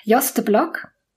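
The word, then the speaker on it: Jos de Blok: Joste Block, ist das richtig ausgesprochen? – Ja, ist richtig genau.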